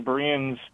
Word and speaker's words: Bereans: So you did answer it in that the Bereans, I think I'm saying that right, the Bereans yes.